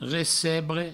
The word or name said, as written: recebre